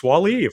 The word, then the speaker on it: Wall-E: There's the one movie, Wall-E, am I pronouncing it correctly? – Oh, yes, Wall-E, of course.